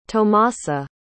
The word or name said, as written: tomasa